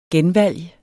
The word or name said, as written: genvalg